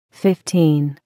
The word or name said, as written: Fifteen